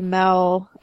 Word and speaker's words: Mel: like when you shorten my name to Mel, it rhymes quite nicely with smell.